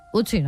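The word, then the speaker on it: Ötzi: Otzi, creo que se llamaba, un nombre muy, muy noruego. – Utzi, ¿no? – Utzi. Pues sí, Utzi. No sé, lo pronuncias tú mejor que yo. – Utzi, ah, vale.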